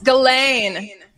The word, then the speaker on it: Ghislaine: You know, like with the whole, how do you say her name? GHISLAINE Maxwell or Helene Maxwell? – It's GHISLAINE.